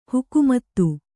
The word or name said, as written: ಹುಕುಮತ್ತು